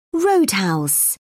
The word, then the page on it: roadhouse: British English